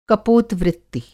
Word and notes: कपोत-वृत्ति: Hindi

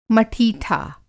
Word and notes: मठीठा: Hindi

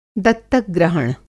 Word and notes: दत्तक-ग्रहण: Hindi

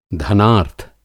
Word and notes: धनार्थ: Hindi

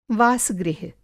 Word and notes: वास-गृह: Hindi

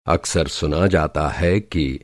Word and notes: अकसर: اکثر

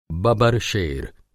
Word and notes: बबरशेर: Hindi

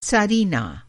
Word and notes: त्सारीना: Hindi

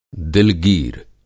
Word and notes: दिलगीर: Hindi

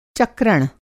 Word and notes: चक्रण: Hindi

